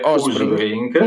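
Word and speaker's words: Åsbrink: Sì, si scrive Asbrink, ma si legge Usbrink.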